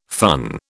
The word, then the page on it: fun: British English